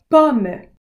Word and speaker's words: Pomme: Pomme, apple.